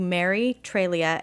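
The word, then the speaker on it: Treglia: And you can go to Mary Trelia, and it's hard because the G is silent.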